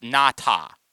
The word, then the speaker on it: Ngata: Where like legitimately his name is actually pronounced Nata, right? But everybody just said Nada for years.